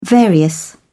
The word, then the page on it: various: British English